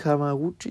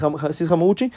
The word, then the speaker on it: Hamaguchi: Y este, sí, sí lo dije bien, Hamaguchi. Sí, sí es, Hamaguchi.